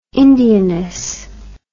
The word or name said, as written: indianness